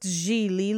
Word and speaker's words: Gigli: Gigli, pronounced Gigli, like really, is the Italian word for lilies,